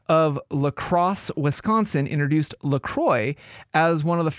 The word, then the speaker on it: LaCroix: Let us know how to correctly pronounce C-R-O-I-X. – So, yeah, and I've gone back and forth with this, and I think it's LaCroix is okay. How they actually do pronounce it.